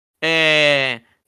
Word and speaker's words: Deadmau5: He actually said, Deadmau5, and I was like, thank you.